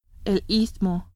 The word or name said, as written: istmo